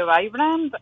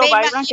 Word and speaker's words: Vibrant: Em, bueno, te, hablo un poquito de la historia de Vibrant. Eh, bueno, yo le digo Vibrant, que es como se pronuncia en inglés.